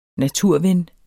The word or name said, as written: naturven